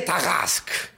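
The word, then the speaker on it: Tarasque: ¿Qué sería la forma apropiada de pronunciarlo, no? – Muy elegante, además. – ¡Le Tarask!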